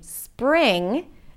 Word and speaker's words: spring: So instead of saying spring, they might add an E sound between the S and the P. Spring. Spring. Same thing with street. Instead of saying street, they might say street. Street. Turning it into two syllables,